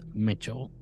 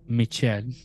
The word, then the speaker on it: Michel: But we're talking about the case that inspired that movie, which is The Exorcism of Anneliese Mitchell, or Anneliese Michel. And where you read it around the world. I don't know. I read it as Anneliese Mitchell. M-I-C-H-E-L. – That's the way I would pronounce it.